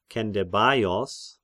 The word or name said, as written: Kendebaios